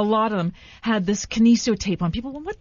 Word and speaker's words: Kinesio: Did I say that right? Kinesio tape. – It's Kinesio tape.